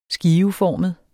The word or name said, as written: skiveformet